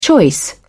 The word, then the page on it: choice: British English